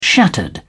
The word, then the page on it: shattered: British English